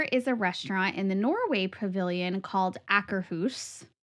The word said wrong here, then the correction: Akershus: We literally just listened to the pronunciation Akershus.